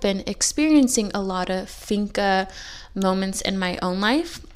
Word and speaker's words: fika: It's finka. It's spelled F-I-K-A, and the definition of finka is a moment to slow down and appreciate the good things in your life.